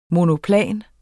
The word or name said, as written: monoplan